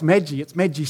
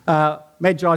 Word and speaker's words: Magi: You know, as a child, I always thought, oh, it's Magi soup. It's just like Magi soup. But no, apparently, there are two ways of saying it correctly. Go figure. Magi is the one I've chosen.